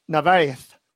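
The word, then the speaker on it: Narváez: Jonathan Narvaez. That's how I say it. That's with the Melendiana. – I call him lisp. – John. It's called John. – John Narvaez. – John Narvaez.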